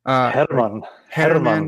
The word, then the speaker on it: Herman: Herman. You have to roll the R a little bit.